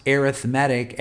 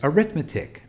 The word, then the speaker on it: arithmetic: Some people pronounce arithmetic as arithmetic mean. I'm going to use the word arithmetic or the pronunciation arithmetic.